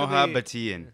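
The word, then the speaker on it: Mohabbatein: That's actually Mahabbatein. – Yeah. They pronounce every single vowel in there. Yeah.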